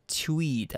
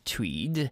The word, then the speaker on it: TUIDE: The team's name is very unique, and that is spelled as T-U-I-D-E. Tweed, everyone. Tweed.